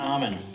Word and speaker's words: Ahmann: Did I pronounce that right, John Ahmann? – Pretty close, John Ahmann.